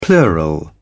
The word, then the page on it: plural: British English